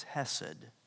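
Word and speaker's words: hesed (חסד): It's hesed. Hesed. We would spell it probably H-E-S-E-D.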